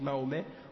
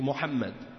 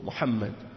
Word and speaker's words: Mohammed: donc ils disent Mahomet au lieu de dire Mohammed. Donc c'est la preuve de leur malhonnêteté. Hein. Donc qu'ils apprennent à prononcer son nom comme il faut, Mohammed.